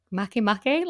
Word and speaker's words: Makemake: Can I just say, Izzy, speaking of mispronouncing things, I don't think I've ever heard anyone say Makemake, like, out loud before. Like, I've been saying make this entire time, and I've just realized that's wrong.